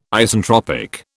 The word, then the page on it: Isentropic: British English